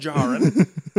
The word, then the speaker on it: Djarin: Probably Djarin, but yeah. – It's pronounced Djarin. – Second D is not pronounced.